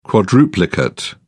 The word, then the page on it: quadruplicate: British English